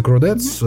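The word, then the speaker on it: Chordettes: Слушай, ну вот есть же еще вот эта песня, да, у э, Кродец, если не ошибаюсь, правильно читается? – Да. Это Кродец, mm-hmm.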